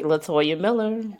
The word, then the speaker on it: Latoya Miller: That's right, Latoya Miller.